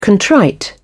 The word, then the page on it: contrite: British English